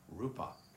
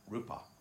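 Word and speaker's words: rūpa: It's clearly taught to be the aggregate of form, what's called in Pali rupa, R long U-P-A, rupa, which means to break apart.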